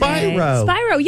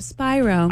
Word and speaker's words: Spiro: Happy birthday to MTV VMA's best new artist, Sienna Spiro. She's 21 today. – Spyro. – Yes. Yeah, Sienna Spiro. – I've been saying it wrong this whole time. Spyro.